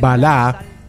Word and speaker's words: Balá: Bueno, él nació como Carlos Salim Balá, con doble A y acento en la última A, hijo de padre de origen sirio y madre croata.